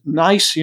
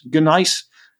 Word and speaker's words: gneiss: The stone is gneiss, you know, spelled G-N-E-I-S-S, gneiss, or usually just pronounced gneiss.